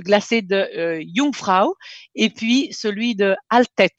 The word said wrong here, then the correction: Aletsch: Alt, Aletsch. – Aletsch, Jungfrau et Aletsch.